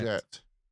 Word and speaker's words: Poyet: It's Poyet. – Is it Poyet? Poyet? – Yeah, like called the Tim Vickery, and I will absolutely trust Mr. Tim Vickery to the hilt.